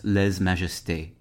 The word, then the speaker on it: lèse-majesté: In modern French, lèse-majesté is pronounced lèse-majesté.